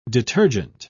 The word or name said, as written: detergent